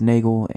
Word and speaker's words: Nagel: So how I got the name for this podcast series, Bagel Bites, obviously my last name is Nagel, and Nagel rhymes with bagel. Nagel